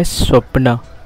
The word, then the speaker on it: स्वप्न: जैसे कि अंग्रेजी में ड्रीम्स कहते हैं, हिंदी में सपना कहते हैं, और मराठी का उससे शुद्ध उच्चारण है स्वप्न।